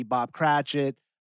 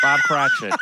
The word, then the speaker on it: Cratchit: whether it be Bob Cratchit. What did I just say his name right? – You did. – Okay. – Bob Cratchit.